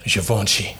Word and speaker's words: Givenchy: Like a young Louboutin, a young Balenciaga, a little Givenchy, Givenchy shirt. – Givenchy. – That pronunciation was smooth.